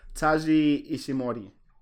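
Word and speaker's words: Taiji Ishimori: Taji Ishimori.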